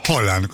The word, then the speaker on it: Haaland: Que en noruego se pronuncia Haaland.